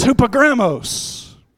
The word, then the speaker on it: hupogrammos: It sounds a little bit like hippopotamus. Amen. But it's hupogrammos, which means, listen to this.